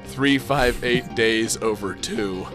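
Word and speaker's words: 358/2 Days: No, just, you know, Google Kingdom Hearts and then type in 358, and it'll show you how it's spelled, and it's pronounced 358 days over 2, and it's fucking stupid.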